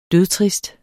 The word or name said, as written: dødtrist